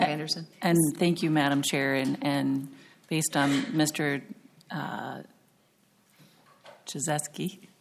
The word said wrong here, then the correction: Kajewski: Did I say that right? Jaszewski? – Kajewski, thank you. – Kajewski, excuse me, apologize.